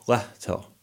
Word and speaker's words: Lehto: I just say Lehto. And that's the American pronunciation of my last name.